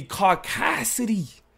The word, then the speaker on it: caucasity: But the jokes, the humor stems directly from the caucasity. – Yeah. – And for those who thought I mispronounced it, no. Caucasity of Stockton Rush, right.